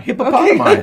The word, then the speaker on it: hippopotami: Well, out of the water come three giant hippopotami. Did I say that right? – Yeah, hippopotami. Okay. The plural in Latin.